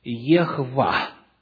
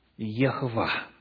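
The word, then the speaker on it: Ехва (יהוה): Оно если верить огласовке, которая дошла до нас благодаря масорецкому тексту, в оригинале звучит так. Ехва. Ехва.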